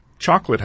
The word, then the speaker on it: chocolate: Chocolate has a central O that's not fully pronounced, and Christmas sounds more like a celebration of someone called Chris, though it celebrates a figure known as the Christ.